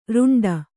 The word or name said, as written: ರೆಂಟೆ